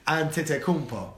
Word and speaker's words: Antetokounmpo: So it's two different ways you can say his name. The Greek way is Giannis Antetokounmpo with a P. Right? Antetokounmpo.